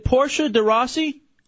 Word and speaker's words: Portia de Rossi: Number two, is it Portia de Rossi? Is that how you say it? – Yeah.